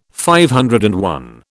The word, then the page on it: DI: British English